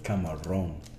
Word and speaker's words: Camarón: Camarón.